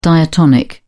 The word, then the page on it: diatonic: British English